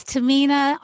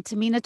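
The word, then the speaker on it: Tahmina: This is Tamina on Tamina Talks Immigration.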